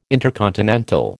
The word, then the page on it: intercontinental: American English